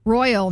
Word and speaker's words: Royale: It's pronounced royal, not royale. This isn't Pulp Fiction.